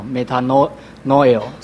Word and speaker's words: metanoia: 那不过我想我们既然是要读圣经，那圣经有原来它的原文，那在原文里面是在新约圣经是用希腊文，所以我们就是看希腊文的原文，它的悔改，希腊文叫 metanoia，大概发音是这样的 metanoia，这个是动词。